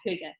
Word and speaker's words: hygge: A lot of people don't know how to pronounce, called hygge. And hygge is a Danish word, and it basically means this idea of calmness and a feeling of well-being and belonging and safety.